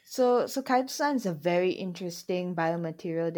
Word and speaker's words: chitosan: It's called chitosan. Am I saying it right? – It's chitosan, yes. Chitosan, so you're right.